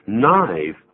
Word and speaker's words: knife: Knife，我们把它听一下好吗？Knife is spelled K-N-I-F-E, K-N-I-F-E, knife. 注意 K 不发音的。